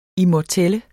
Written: immortel

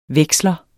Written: veksler

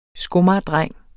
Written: skomagerdreng

